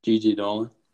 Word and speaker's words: Gigi Dolan: Gigo Dolan. – Gigi Dolan.